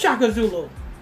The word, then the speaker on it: Shaka Zulu: It's Shaka. You said it right, though. You said it right. Go ahead. – She's saying it correctly, Pastor. – Huh? – She's saying it correctly. – His name is Shaka Zulu. – That's exactly what it is.